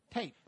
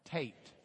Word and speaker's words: tet: Tate. Let's say it all together. Tate. Rhymes with hate. Tate. So we've got hate, Tate. An easy rhyme.